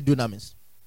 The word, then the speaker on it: dunamis: Say dunamis. Dunamis is spelled D-U-N-A-M-I-S, dunamis.